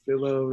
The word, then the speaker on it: Philo: Uh, my name's Philo, rhyming with Willow, and um, I've actually been watching the broadcast since it started.